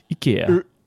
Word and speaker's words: IKEA: I say it like they say it on the ad. IKEA. It's really interesting. There's an ad in the UK and it's narrated by a Swedish person and they say it more like IKEA.